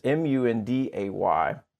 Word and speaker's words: Munday: your name is said Mundy. Is this true? – That's correct. – So we actually say your name Mundy? – And that is the most intelligent, because that's the way they say Monday here in the South. But what's funny is, like, even though that's the Southern way of saying Monday, it's MUNDAY. So if you type like Monday Martin, MONDAY, you'll never find me.